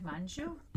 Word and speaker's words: Manjoo: The one I just mentioned, I've Seen a Future Without Cars and It's Amazing by Frahad Manjo. How do you say that? M-A-N-J-O-O. Give me a pronunciation check on that one. – I would go Farhad Manjoo.